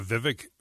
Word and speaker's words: Vivek: Yeah, Vivek Ramaswamy.